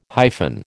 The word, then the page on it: hyphen: American English